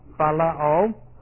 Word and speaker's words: phileo: That's a P-H sound, P-H-I-L-E long O, phileo.